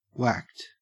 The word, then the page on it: whacked: Australian English